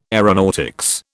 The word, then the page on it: aeronautics: British English